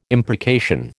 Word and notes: imprecation: American English

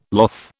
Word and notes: loth: British English

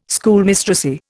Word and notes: Schoolmistressy: Indian

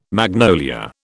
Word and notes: magnolia: British English

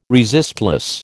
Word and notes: resistless: American English

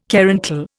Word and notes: caruncle: Indian

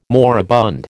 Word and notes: moribund: American English

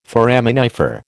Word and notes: foraminifer: American English

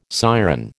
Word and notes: siren: American English

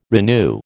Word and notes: renew: American English

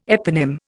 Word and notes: eponym: Indian

